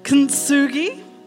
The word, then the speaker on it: kintsugi: It's called, oh my goodness, I listened to a tutorial on how to say it, kintsugi.